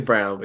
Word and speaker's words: Braun: So Christian Brown. Isn't that how you pronounce his name? – I did that half of the year last year too. So it's Brown, but yeah.